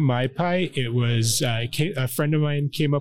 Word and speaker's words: Mai Pai: So she said, well, it's pizza and a tiki bar, so it's got to be My Pie. – And it's spelled M-A-I-P-A-I.